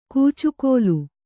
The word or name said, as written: ಕೂಚುಗೋಲು